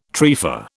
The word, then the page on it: trefa: British English